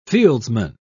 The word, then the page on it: fieldsman: British English